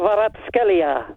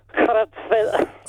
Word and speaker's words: Kvaratskhelia: Kvaratskhelia. There you go, that's how you say it. – Try it one more time. – Kvitsia Kvaratskhelia.